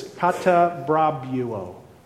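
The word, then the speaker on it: katabrabuo (καταβραβεύω): That's the Greek word, katabrabrio. I've mispronounced it. It's the word for rule. It's katabrabuo. Katabrabuo.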